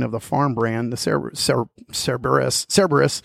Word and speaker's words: Cerberus: How do you say it? Cerberus? – Cerberus.